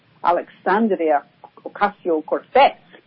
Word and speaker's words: Alexandria Ocasio-Cortez: which is Alexandria Ocasio-Cortez, and she's the one, of course, who, did I say that right? – Yeah, you were pretty good.